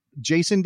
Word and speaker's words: Jason: Jason Davis? You're like, it's Hasson. Just spell it Hasson.